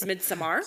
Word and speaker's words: Midsommar: Do you see the A? Yeah. It's not an E. It's Midsommar.